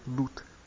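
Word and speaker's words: luth: então se pronuncia lutte,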